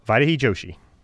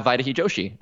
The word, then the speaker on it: Vaidehi Joshi: Is that the right way to pronounce the name? – Vaidehi Joshi. – We're going to have Vaidehi Joshi.